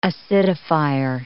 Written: acidifier

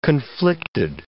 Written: conflicted

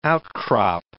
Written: outcrop

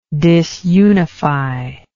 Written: disunify

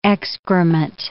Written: excrement

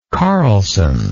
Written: carlson

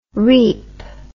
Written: reep

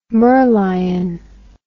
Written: merlion

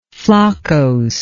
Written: floccose

